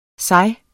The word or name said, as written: sig